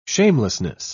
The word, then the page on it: shamelessness: British English